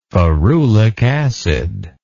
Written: ferulic acid

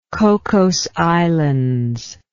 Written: Cocos Islands

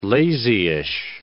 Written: lazyish

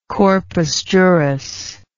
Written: corpus juris

